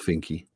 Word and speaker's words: Finke: is it Finke? – Yes, it's pronounced Finke.